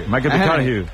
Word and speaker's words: McConaughey: McConaughey.